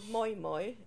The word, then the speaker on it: moi moi: Could you tell me something about this mau mau and how does it start from the beginning, please? – It's called moi moi and it's spelled MOIMOI.